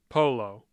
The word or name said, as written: Polo